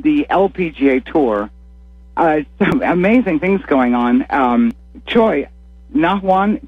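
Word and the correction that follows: Na Yeon Choi: Choi, am I mispronouncing her name completely? – Close. Nayan Choi.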